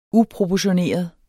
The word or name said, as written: uproportioneret